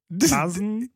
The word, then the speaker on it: DAZN: Dazn? Well, it's pronounced Dazn. – Dazn? Yeah. It's spelled fucking D-A-Z-N. Dazn. That's the way I pronounce it.